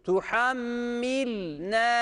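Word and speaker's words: Tuhammilne (تُحَمِّلْنَا): Tuhammil, tuhammil, tuhammil dersek yanlış olur. Hareke vermiyoruz şu lema, doğru okuyoruz. Tuhammilne diye okuyoruz.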